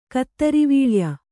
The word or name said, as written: ಕುದುರೆವಳೆಯ